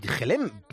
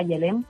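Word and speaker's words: Gelem: que en verdad se escribe con G, Yelem, pero tú lo has llamado como realmente se pronuncia.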